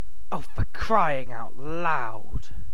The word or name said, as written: for crying out loud